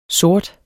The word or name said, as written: sortere